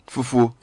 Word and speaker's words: Fufuo: The town is spelled F-U-F-U-O. It's not Fufuo. – Fufuo. – It's Fufuo. White.